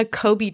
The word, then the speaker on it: Kolbe: And if you've never done a Kobe test before, highly recommend it.